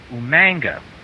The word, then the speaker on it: Umaga: In the Samoan dialect, the G is actually pronounced NG. That is why professional wrestler William Regal used to pronounce his name Umanga, with that A-N-G sound.